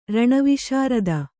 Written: ರಣವಿಶಾರದ

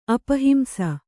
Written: ಅಪಹಿಂಸ

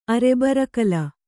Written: ಅರೆಬರಕಲ

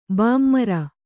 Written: ಬೆಮರ್

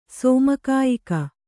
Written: ಸೋಮಕಾಯಿಕ

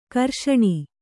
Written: ಘರ್ಷಣೆ

